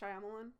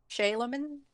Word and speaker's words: Shyamalan: Did I say his name last name right? I think it's Shyamalan. – M. Night Shyamalan. – Shyamalan. I've heard of him a lot of different ways. I think Shyamalan.